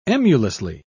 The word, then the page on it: emulously: British English